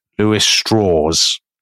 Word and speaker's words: Strauss: is a man called it's spelt Louis Strauss, but he always pronounces it Louis Strauss. I will explain why. He's from West Virginia. He is also of German Jewish stock. But to downplay his Jewishness in a world that is still quite anti-Semitic, he pronounces it Strauss because he kind of thinks it'll sound more Southern and less Jewish.